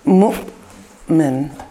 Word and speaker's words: Mu'min: Yes, it's M-U, but then an apostrophe, and then a lowercase M-I-N. So it's like Mu'min, Mu'min, Mu'min. Sometimes we hear it Mu'min. We've heard it pronounced that way, but in the classical sense.